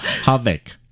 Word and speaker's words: Havoc: 我们刚才讨论一下这个英文怎么发音 Havoc，虽然它是 H-A-V-O-C，但那个 O 不知道为什么就念成 A 的音 Havoc，